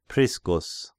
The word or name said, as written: Priscus